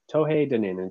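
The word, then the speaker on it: Tuatha Dé Danann: And that comes down to the Tohei Dunin. Tohei Dunin, it's spelled way differently.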